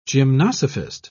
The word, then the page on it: gymnosophist: British English